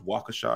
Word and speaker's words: Waukesha: I would target that to people who live in Milwaukee, Raceon, Kenosha, Waukesha, which is pronounced Waukesha, I found out last night. – Not Waukesha. It's not Waukesha.